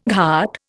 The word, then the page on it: ghat: Indian